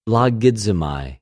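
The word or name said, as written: λογίζομαι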